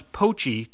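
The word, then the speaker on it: Pochet: Poachet, which you have to pronounce Poachy to prove you're a local, is well known as a premier fall birding spot on Cape Cod.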